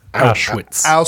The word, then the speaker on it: Auschwitz: And so, like, there's really good levity because there's flashbacks of all this awful stuff happening at Auschwitz. Did I say that right? – Auschwitz.